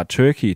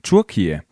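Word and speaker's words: Türkiye: Det engelske navn for Tjekkiet, som udtales Turkey, kan lede tankerne hen på en stor fugl, nemlig en kalkun. Men det vil Tjekkiet ændre på. De vil nemlig skifte navn fra Turkey til Turkiye.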